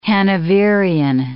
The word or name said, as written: Hanoverian